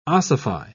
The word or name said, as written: ossify